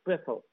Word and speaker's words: Pfeffel: Se escribe, esto es un apellido que es de origen alemán, se escribe con P primero, F-E-F-F-E-L, que parece Eiffel, pero no es Eiffel, y va con P, Pfeffel Johnson.